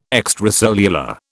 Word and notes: extracellular: British English

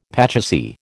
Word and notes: pachisi: American English

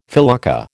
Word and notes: felucca: American English

